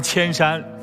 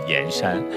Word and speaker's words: 铅山: 刚才的邹韵特别求证了一下江西上饶的那个地名到底是读什么，这个地方有一个永平镇，那附近真的以前就出铅矿，那它为什么不叫铅山非得叫盐山呢？现在没有一个一定的说法，当地沿袭下来的读音就叫盐山。